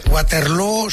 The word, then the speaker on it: Waterloo: Waterloo se pronuncia igual que Feijóo.